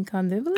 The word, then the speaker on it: Kandivali: But he couldn't pronounce it correctly, so he would always say Candy Valley, Candy Valley. I'm like, you mean Kandivali? – Kandivali.